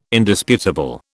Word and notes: Indisputable: British English

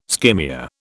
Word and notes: skimmia: British English

